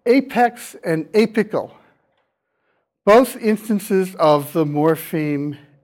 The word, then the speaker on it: apical: A-P-E-K-S. And for apical, I'm going to spell the letter before the final syllable phonetically. It's pronounced as a K. So apical.